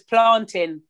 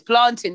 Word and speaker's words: plantain: Also, it's planting. What's this plantain behaviour? – Yeah. – No. Nathan was all about the planting, Yeah, it's planting because we're Caribbean.